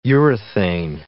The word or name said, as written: urethane